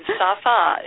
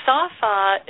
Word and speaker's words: Safa: And I apologize, I pronounced it incorrectly. – No, that's fine. Safa is how you say it. Safa